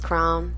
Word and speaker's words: crayon: Crayon. – Crayon. – My sister's fiance says crown. – Crayola. – Her husband, what is that? – That's how they say it in Kansas City, crown.